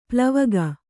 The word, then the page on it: ಪ್ಲವಗ: plavana